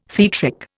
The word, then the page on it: theatric: Indian